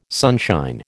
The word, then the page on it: sunshine: American English